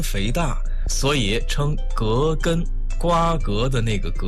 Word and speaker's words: 葛根: 我们身边有很多朋友都把葛根读作葛根，姓葛的那个葛。其实啊，这个药的正确读音为葛根，瓜葛的那个葛。